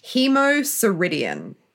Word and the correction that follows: hemosiderin: No, I've definitely said that wrong. Hemosiderin? Oh, no, this is why... – Hemosiderin.